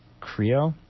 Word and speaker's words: Qrio: I believe it's pronounced Creo, Q-R-I-O.